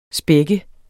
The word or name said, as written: spække